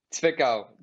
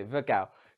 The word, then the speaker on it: Zwickau: Zwickau. The W is like a V, almost. – Okay, Zwickau.